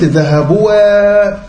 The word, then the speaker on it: ذهبوا: يقول الأصل في الكتابة أن يتساوى اللفظ والخط، يعني اللي الواحد بيقوله هو اللي إيه هو اللي يتكتب، وقد يزيد الخط على اللفظ نحو ذهبوا. ايه اللي زاد هنا؟ انا قلت ذهبوا قلت كده، قلت ذهبوا، اللفظ اللي انا لفظته اخر حاجه تلفظت بيها ايه؟ الواو. طب ايه اللي زود الالف؟